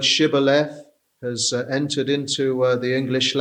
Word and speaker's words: Shibboleth: If you can't say the sh and you say Sibboleth, you are an Ephraimite, and so therefore they would slaughter them.